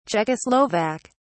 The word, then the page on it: czechoslovak: British English